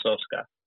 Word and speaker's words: Soska: They should get I don't know if you're familiar with I think it's pronounced Soska.